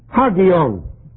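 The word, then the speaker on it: Hagion (ἅγιον): And hagion, or hagion. H-A-G-I-O-N. And the accent is on the last syllable. That is on the I, really. Hagion.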